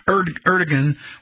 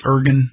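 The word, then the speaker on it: Erdoğan: This is where Erdogan, what's his name? I forgot how to pronounce his name. I think it's Ergun. It looks like Erdogan, but it's Erdogan, the Turkish president.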